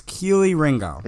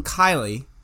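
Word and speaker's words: Kelee Ringo: After numerous highlights and separate announcers pronouncing this man's name, I've concluded that it is Keely Ringo. – Mm-hmm. Now, some people call them Kylie, and some people call them like close to Kelly, mm-hmm.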